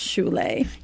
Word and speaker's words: shule: You're a foundation called the Shul Foundation, which is Swahili for schools, and it's actually pronounced shule.